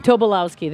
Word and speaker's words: Tobolowsky: An evening with Stephen Tobolowsky. I wish I could pronounce it. – Tobolowsky.